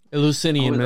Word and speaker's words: Eleusinian: Eleusinian mysteries.